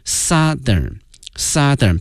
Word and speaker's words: Southern: 但是啊同学们记住了，同时啊我们原来已经讲过南方这个词 south 如果说变成形容词之后呢，对它的这个发音会有一定的影响，从原来的大口型这个 south 变成了 Southern 变成小口型这个啊，从原来那个凹音变成了小口型这个啊 Southern，然后我们加一个同样是一个最高级 most， The southern most city。